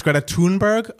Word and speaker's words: Greta Thunberg: All right, we were debating how to say her name. I believe it's Greta Thunberg, according to um, Matt Steele. If it's not, it is now.